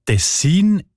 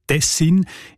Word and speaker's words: Tessin: Die Variante mit der Betonung auf den ersten Silben und mit kurzem I, also Tessin, ist seltener.